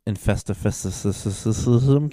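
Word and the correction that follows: Infestissumam: I can't fucking say it. How would you say it? – Infestissimum. – Yeah, what he said came out.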